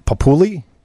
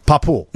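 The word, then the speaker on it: Papu (παππού): And last but not least, how do you say grandpa in Greek? Papouli? – Papu.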